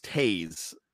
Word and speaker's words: Toews: I say his last name wrong. – I believe it's Taves, T-O-E-W-S.